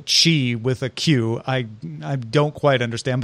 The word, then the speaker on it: Qi: But the Wireless Power Consortium does say, and they manage the standard, that it should be pronounced Qi because, yes, it is based on the Chinese character.